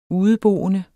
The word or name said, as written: udeboende